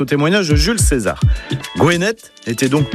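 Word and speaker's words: Gwened: Alors son nom breton, c'est Gwenet ou Djuenet, en prononciation du breton du Morbihan.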